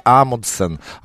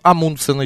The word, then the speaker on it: Амундсен: Конечно же, первым достиг Южного полюса это Руаль Амундсен. Амундсен еще ударение ставит в некоторых словарях.